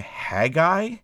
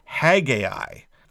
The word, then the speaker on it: Haggai: Yeah, and as a quick note, you can pronounce the name Haggai if you want to. That's what they do in the audio scriptures. However, there's a great controversy, and maybe this is tearing apart your household, but the primary song pronounces it Haggai, or closer to what John was saying, Haggai.